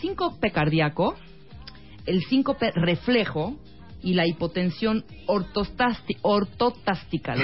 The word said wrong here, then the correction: ortostática: ¿Lo dije bien? ortostática, ortostática. Ortostática.